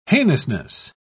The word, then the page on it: heinousness: British English